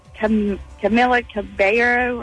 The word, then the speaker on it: Camila Cabello: I can never say her name right. Camila Cabello or something like that. – Yeah, you did a good job.